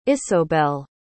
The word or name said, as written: isobelle